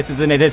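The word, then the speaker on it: 涼音: オーディションとかでよくやる、あの、岡部すずねです。涼しい音と書いてすずねと言いますって、あれやらないんだ。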